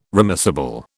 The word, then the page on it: remissible: British English